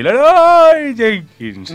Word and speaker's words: Leroy Jenkins: Porque se dice así, ¡Leroy Jenkins!